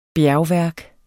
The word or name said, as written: bjergværk